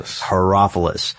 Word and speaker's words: Herophilus: Herophilus.